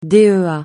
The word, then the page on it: DEA: francais